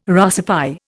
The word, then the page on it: Russify: Indian